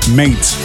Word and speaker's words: M8: The artist is Miami House Party with the extended mix of M8. Or probably, as you should pronounce it, mate.